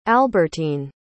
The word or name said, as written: albertine